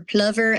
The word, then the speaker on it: plover: It has the island itself, a little plover bird, or plover as they pronounce it in England, marum grass, the ocean, a bunny, and then of course St. Aidan himself.